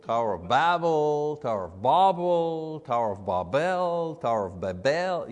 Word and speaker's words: Babel: You know, there's a million ways to say it, but I've looked at the phonetics of it, you know, and the phonetics say the pronunciation should be B-A-W-B-E-L, so Babel, Babel.